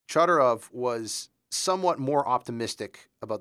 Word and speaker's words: Chodorov: One of his students, and you'll have to help me on the pronunciation, Frank Chodorov? – Chodorov. – It is Chodorov.